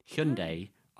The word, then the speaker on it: Hyundai: The Hyundai, got to get the pronunciation right, like Sunday. Hyundai Ioniq 6.